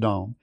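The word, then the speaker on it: dom (דם): In the Hebrew, the word for blood is dam. It's spelled D-A-M, but pronounced dom.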